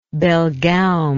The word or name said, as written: belgaum